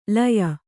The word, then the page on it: ಲೀಯ: līya